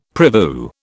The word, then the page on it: prevue: British English